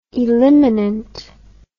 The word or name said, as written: eliminant